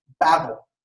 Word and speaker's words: Babel: In Hebrew, the place that these people are described as building is simply referred to in the text as Babel. That's kind of how the word would be pronounced in Hebrew.